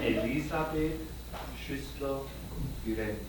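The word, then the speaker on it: Elisabeth Schüssler Fiorenza: And it's a basis, if you read the book by Elizabeth Slusser Forenza. Did I say that right? It's Schussler. Say it in German. – Elisabeth Schussler Forenza.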